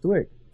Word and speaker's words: Dwyck: It's Dwick.